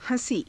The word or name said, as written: ha~sii